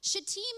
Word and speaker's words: Shittim: Shittim is pronounced Shittim, because I looked it up to make sure I wasn't saying it wrong, because you could easily say that word wrong.